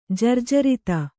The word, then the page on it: ಜರ್ಜರತೆ: jarjarate